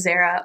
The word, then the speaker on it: Zarah: When I first joined this brokerage, nobody could decide if my name was Zara or Zara.